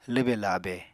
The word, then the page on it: lwbe-labe: Apatani